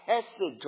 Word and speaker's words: chesed: And that's our favorite word, chesed. If someone asks how you spell that, you could spell it like this. C-H-E-S-E-D. It's kind of a hard sound at the beginning. Chesed.